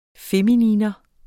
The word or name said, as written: FEMININA